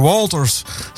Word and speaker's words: Walters: En wij kwamen daar als allereerste kwamen we daar tegen Chandler Walters. Zo moet ik het uitspreken. En je schrijft het als W-A-L-T-E-R.